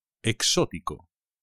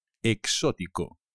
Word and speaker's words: exótico: Exótico. Cuidado con el acento. Exótico.